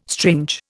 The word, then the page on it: strange: Indian